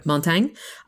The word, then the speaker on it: Montaigne: And then I also felt like this performer's Montaigne, I think it's pronounced, yeah. – Montaigne.